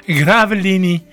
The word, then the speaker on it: Gravelines: Gravelines, dat zou wel op zijn Frans uitgesproken moeten worden. Gravelini is stilgelegd vanwege een kwallenplaag.